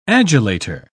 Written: adulator

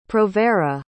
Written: provera

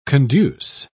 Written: conduce

